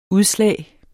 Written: udslag

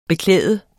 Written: beklæde

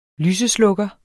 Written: lyseslukker

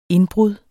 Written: INDBRUDDETS

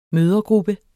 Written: mødregruppe

